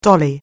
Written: dolly

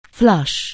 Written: flush

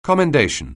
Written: commendation

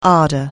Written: ardent

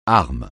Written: arme

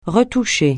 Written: retoucher

